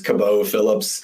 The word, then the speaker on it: Cabot Phillips: You did not butcher it. Sometimes when I'm feeling sophisticated, I go with the silent T and just Cabot Phillips. – Cabot. – We'll stick with Cabot for now.